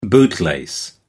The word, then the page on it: bootlace: American English